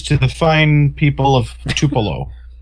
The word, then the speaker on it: Tupelo: It was, you pronounced it Tupelo, and it was Tupelo.